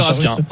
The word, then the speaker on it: Raskin: Ils m'ont expliqué, mais alors on dit Raskin, ok.